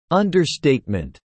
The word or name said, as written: Understatement